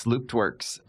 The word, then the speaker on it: LoopedWorks: Can you first tell us, how do you say LoopedWorks? And tell us a little bit about your business. – You nailed it. It's LoopedWorks. It actually is a nod for closed loop manufacturing, which is where we need to go from a long-term standpoint.